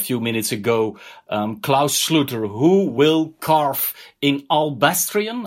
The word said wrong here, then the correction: alabaster: How do you say it? – Alabaster.